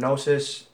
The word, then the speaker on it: gnosis: The word gnosis, it's spelled G-N-O-S-I-S.